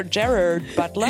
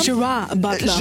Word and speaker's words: Gerard Butler: Ger Ger Gerard Butler. – Gerard Butler. Äh, Gerard Butler, natürlich. Die Amis, die machen das ja liebend gerne, dass sie halt Dinge, die eigentlich Englisch sind, auf Französisch aussprechen.